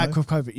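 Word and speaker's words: Akrapovič: Is that how you pronounce it? – I can say Akrapovic. – Does anybody know? – Akrapovic, yeah. – Akrapovic. Let's go with that.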